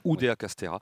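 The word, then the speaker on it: Oudéa-Castéra: Oui, Denis, c'est vrai que cette entrevue, parce qu'elle s'est exprimée par deux fois, la ministre, Oudéa Castéra, excusez-moi, je l'avais mal prononcé tout à l'heure. – Castéra. – Oudéa Castéra.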